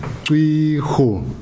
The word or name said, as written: cwi